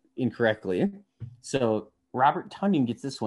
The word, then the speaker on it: Tonyan: Finally came out and said his last name is pronounced Tonyan, like Funyuns.